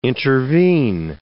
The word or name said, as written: intervene